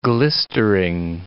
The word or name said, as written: glistering